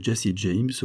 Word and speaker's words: Jesse James: Enfin, il est indéniable que Jesse James, aux consonances incisives, nom que l'on peut prononcer en chassant par 2 fois l'air d'entre les dents, présente des références pour voguer sur le radeau de l'immortalité et pour devenir le héros d'un film.